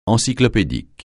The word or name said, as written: encyclopédique